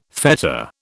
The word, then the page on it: fetter: British English